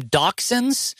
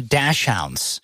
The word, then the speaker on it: dachshund: Hot dog, which some people call them dachshunds, some people call them dashhounds, because dachshund is spelled dashhound. – Yeah, it is. – And I know people that say dashhound is the proper way to pronounce it, and then some people say dachshund is the proper way to pronounce it.